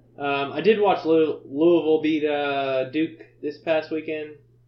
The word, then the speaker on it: Louisville: Well, we got no line on sticking with Florida State playing against Louisville. Sorry, Louisville is how they pronounce it.